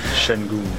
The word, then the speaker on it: Şengün: But, nah, all praise to, I don't know how to pronounce his name, but number 28, Şengün.